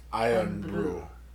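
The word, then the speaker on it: Irn-Bru: It's spelled I-R-N space B-R-U. But it's supposed to be pronounced Iron Brew.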